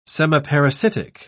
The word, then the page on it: semiparasitic: British English